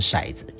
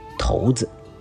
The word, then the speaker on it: 骰子: 啊，一般我们念成骰子哈，可是事实上呢，这个字啊，就是左边一个骨头的骨哈，右边呢好像是这个呃没有的没的右半边哈，这个字呢其实不能念成骰子，要念成头子啊，正确的读音念成头子啊，但是你平常如果念成头子的话呢，恐怕没有人会听得懂啊，所以我们大家都讲成啊这个掷掷骰子啊，那么这是我们平常的一般的呃这个常常。